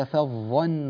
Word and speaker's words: فظا: بعض الناس يفخم النون وبعضهم يفخم الفاء بسبب مجاورتها للظاء، فا فظا لا غير صحيح، ولو كنت فظا غليظ القلب كذلك الحال في هذه الفاء لانفضوا من حولك بترقيق الفاء.